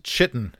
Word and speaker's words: chitin: I hear it pronounced chitin and chitinous all the time on D&D YouTube channels. And hey, that's great for them. That is not correct.